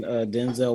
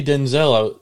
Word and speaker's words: Denzel: Fun fact, we've been pronouncing Denzel Washington's name wrong our whole lives. – Why was his name? – Because he pronounced his name Denzel, not Denzel.